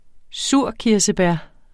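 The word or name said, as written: surkirsebær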